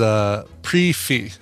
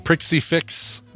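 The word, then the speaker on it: prix fixe: And the pronunciation is prefee. – Oh, prefee? – The prixie fix is pronounced prefee.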